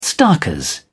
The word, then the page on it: starkers: British English